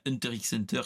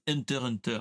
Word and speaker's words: Hunter x Hunter: Mais normalement, c'est pas Hunter x Hunter, c'est Hunter Hunter parce que nous a dit dernièrement que c'était la bonne euh, la bonne prononciation.